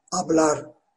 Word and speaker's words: hablar: H harfi yazılır ama okunmaz. İspanyolca da öyle. Az önce konuşmak kelimesini vermiştim. Hablar diye resmi İspanyolca'da. Hablar okunur fakat hablar Hatay'ın H'siyle yazılır.